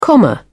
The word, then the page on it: comma: British English